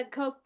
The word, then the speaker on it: Koch: David Cock and Charles Cock. Does that mean anything to you? – No. – I think that's how you pronounce their name. They own the Cock Industries. Or is it Coke? – Oh. – Is it Coke? – Yeah. Yeah, the Koch brothers.